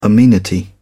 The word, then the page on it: amenity: British English